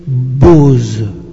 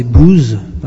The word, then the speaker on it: Boaz: Boaz, en anglais Boaz, en français c'est B2OZ, qu'il ne faut pas prononcer booze, parce qu'en anglais ça veut dire l'alcool.